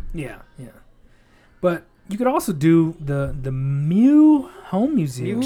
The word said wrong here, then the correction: Meux: Mewks. You do pronounce the X. Mewks. – Yeah. So they're not French? – That's not French, no. – M-E-U-X.